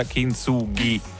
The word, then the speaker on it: Kintsugi: Si pronuncia Kintsugi. – Avrei detto Kintsugi, sinceramente.